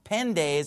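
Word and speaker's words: Pende (πέντε): So the Greek number 5 is pende, P-E-N-T-E. Pende is how you pronounce it in modern Greek.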